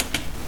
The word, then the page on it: observance: American English